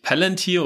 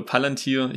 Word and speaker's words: Palantir: Und dann würde mich noch interessieren, sagt man eigentlich Palantir oder Palantir? Ich glaube, ich habe Palantir gesagt. Palantir oder Palantir. Das eine ist wahrscheinlich die englische Aussprache, das andere die deutsche. Beides ist für den Podcast heute hier in Ordnung.